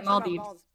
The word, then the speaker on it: Maldives: How do you pronounce the Maldives? – Yeah, Maldives.